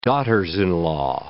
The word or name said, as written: daughters-in-law